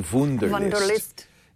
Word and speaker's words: Wunderlist: And that's what Wunderlist, or maybe it should be Wunderlist. It's spelled W-U-N-D-E-R.